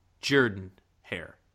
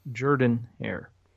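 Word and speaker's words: Jordan-Hare: It is, by the way, pronounced Jordan Hair. – It is Jordan Hair. Correct.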